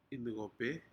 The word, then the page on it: incope: Aneityum